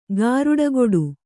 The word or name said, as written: ಗಾರುಡಗೊಡು